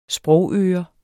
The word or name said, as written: sprogøre